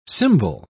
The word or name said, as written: cymbal